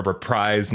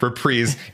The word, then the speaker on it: reprise: and now it's like a reprise now. How do we say that word? – Reprise. – Apparently, you say this word reprise.